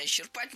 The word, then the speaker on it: исчерпать: Это слово прошло долгий путь и поменяло ударение. Раньше нормативным был вариант исчерпать. Только он один, никаких вариантов. Потом появилась двойная норма. Исчерпать и исчерпать. Но по последним сведениям, нормативным считается только вариант исчерпать.